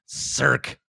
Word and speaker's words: Cirque: Cirque. Like Cirque du Soleil.